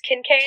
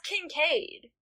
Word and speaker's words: Kincaid: How have you been pronouncing his last name? – Will you spell it for me? I don't remember. – K-I-N-C-A-I-D. – Oh. – Just Kincaid? – That's Kincaid. – Well, okay, yeah. That's what I was thinking, but I was worried that that was too obvious. – No, it's Kincaid. – Maybe this was a last name where it's not pronounced the obvious way.